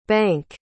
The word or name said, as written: banque